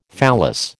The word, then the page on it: phallus: American English